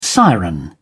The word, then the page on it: siren: British English